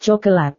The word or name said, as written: cokelat